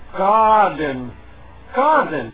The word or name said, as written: garden